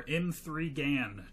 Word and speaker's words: M3GAN: I watched Megan, or M3GAN, depending on how you pronounce the title.